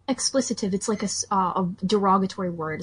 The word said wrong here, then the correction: expletive: Or maybe it's pronounced expletive. I don't know. It's expletive. That's the one you're looking for, expletive.